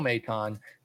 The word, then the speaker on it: Maton: I haven't really watched Nick pitch or Nick play either, but his brother Phil Maton, Maton, M-A-Y-T-A-H-N is the pronunciation guide.